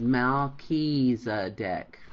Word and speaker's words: Melchizedek: He said Melchizedek.